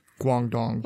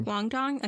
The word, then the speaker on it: Guangdong: Guangdong. – Guangdong, okay.